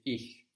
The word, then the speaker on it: ich: It's ich, soft.